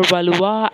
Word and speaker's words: Valois: Henry V further cements this alliance with France by marrying daughter of Charles VI, Catherine of Valois, or Valois as correctly pronounced.